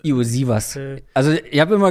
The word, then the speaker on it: Iosivas: Ich weiß nicht, was genau seine Eltern, wo, wo da die Familienwurzeln liegen, aber ähm, ich habe es nachgeschaut und der, die, die Aussprache ist Yoshi was.